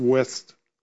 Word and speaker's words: Wuest: I have a translation called the WUEST, I believe it's pronounced West translation.